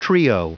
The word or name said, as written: trio